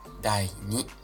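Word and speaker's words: 第二: E como é que fala segundo? Dai Ni.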